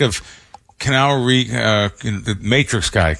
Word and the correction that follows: Keanu Reeves: Am I saying his name right? Canal Reeves? – Keanu Reeves.